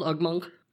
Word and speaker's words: Ugmonk: Is it called Ugmonk? – Yep, that's how you say it.